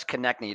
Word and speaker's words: Konecny: Travis Konecny did a great job there.